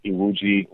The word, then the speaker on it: Iwuji: So just go find Jesse Iwuji Racing. And name is spelled J-E-S-S-E, last name I-W-U-J-I, and then racing.